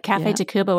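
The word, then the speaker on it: Café Tacvba: I think that one of the greatest examples of that in the season ahead is Cafe Tacuba. Did I say that right? – You did. Cafe Tacuba.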